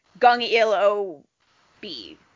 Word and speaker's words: 015B: There's two people, but it's pronounced in Korean, so it, other than the B. So it's like, Gung Il O B.